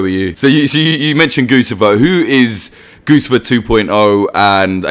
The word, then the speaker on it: Guccifer: Some people say Guccifer. I always say, like, Guccifer as in goose, and, like, I'm not going to change, even though I kind of know it's wrong.